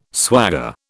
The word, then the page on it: swagger: British English